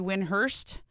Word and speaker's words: Windhorst: Do you know that Brian Windhorst is actually Winhurst, and that's how he actually pronounces it, Winhurst.